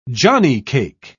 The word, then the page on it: johnnycake: British English